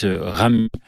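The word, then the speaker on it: Ramuz: C'est l'auteur suisse Rami, avec un Z à la fin, les Suisses ne prononcent pas le Z, qu'il a écrit et qu'il a écrit en 1918.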